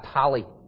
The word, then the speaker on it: Halle: I think it's Halle, it's H-A-L-L-E. How would you pronounce that? Halle.